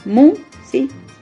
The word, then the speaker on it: Música: Música. ¿Cuántas sílabas tiene? Música. Tiene tres sílabas y entonces lleva acento en la tercera sílaba, que es la antepenúltima.